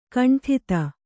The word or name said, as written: ಖಂಡಿತ